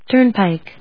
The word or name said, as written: Turnpike